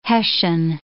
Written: hessian

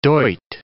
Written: duit

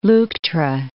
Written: Leuctra

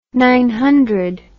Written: nine holes

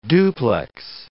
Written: duplex

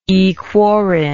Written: aequorin

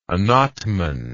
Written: anatman

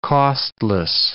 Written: costless

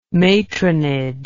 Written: matronage

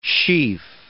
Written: sheaf

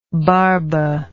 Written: barba

